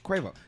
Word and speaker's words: Quavo: After like a really awesome experience at one show, even though we almost killed Cuervo with lip gloss. Cuervo, am I saying that right, Migos? – I think so. – Quavo.